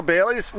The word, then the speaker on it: Baillie: That's BAILLIE. I had to ask Sean, how do you pronounce that? Because I didn't want to like make a big mistake on Wage World here, and it's Bailey. He said, don't you know Bailey's?